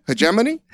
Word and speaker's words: hegemony: Is it hegemony? Hegemony? – Hegemony. – Hegemony. What? And I said hegemony, right? – Yeah.